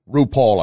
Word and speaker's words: RuPaul: You know who Raw Pool is? RuPaul, I should say. I mispronounced it, and I'm glad I did.